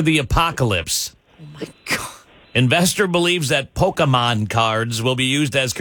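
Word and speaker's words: Pokémon: Investor believes that Pokemon cards, did I say it right this time? – No, you did not. – Pokemon? Investor believes that Pokemon there you go.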